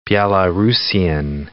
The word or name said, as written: Belarusian